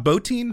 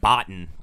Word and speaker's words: Bottin: And it was up to the maker of the suit, so let's say his name, Rob Bottin. – Yes, is that how you pronounce it? – Rob Bottin. – I just said Botten.